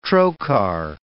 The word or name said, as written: trochar